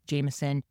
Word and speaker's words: JMSN: This really great artist, Jameson, J-M-S-N, but pronounced Jameson, really, really great, kind of embodies our comeback theme as well.